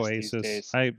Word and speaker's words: Asus: I go Asus. That's Asus.